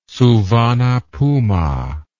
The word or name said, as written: Souvanna Phouma